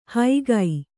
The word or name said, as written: ಹೈಗೈ